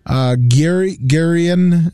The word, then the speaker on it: Geirean: Gurion Hatchin, Washington, 2020. He He's an offensive lineman. – What was that name you just butchered? – Geirean, G-E-I-R-E-A-N.